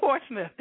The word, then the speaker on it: Portsmouth: And how do I say it again? Portsmouth. Did I get it right? – Portsmouth. – Portsmouth.